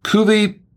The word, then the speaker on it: cuvée: Cuvée, it's pronounced cuvée, actually.